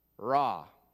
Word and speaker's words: ra: The word that Jacob uses here for evil is pronounced ra. Ra. Which you remember is the same pronunciation for the Egyptian sun god.